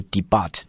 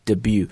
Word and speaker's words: debut: be not very stable, 表示的意思可能就是不稳定啊，比较这个晃晃悠悠的或者是来回震荡的美国股市的这样的一个处女秀或者是首演，这个词叫做 debut。注意这个词的读法，很多人呢是见到这个词直接就读了，读 debut, no, but it's debut, it's a French word, so debut, 啊，特也不发音 debut, read after me three times, debut, debut, debut, OK, debut, 然后往后看 as it launched on the Nasdaq, 上市很多词啦，比如说 launch, 比如说 list, 都可以啊，或者是 go on public, go for public, those words are also have the same meaning, OK?